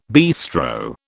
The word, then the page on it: bistro: British English